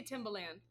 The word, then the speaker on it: Timbaland: That, is that how you say it, Timbaland? – I say Timbaland. – Mm-hmm. Timbaland, yeah. Listen to me with my Michigan accent.